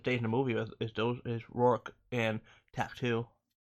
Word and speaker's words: Rourke: Rourke. – Mr. Rourke. Yeah, you said it right. How you said it is how you say it.